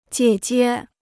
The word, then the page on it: 姐姐: jiě jie